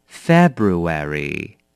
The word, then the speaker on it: February: 好，再来第八个字 ,February, February, 好，这个是二月，这个字有一点点的难。好了 ,February, 你可以看到 February, F-E, February, B-R-U, 后面 A-R-Y, 念成 Airy, 所以 February, February, OK, 就是二月份。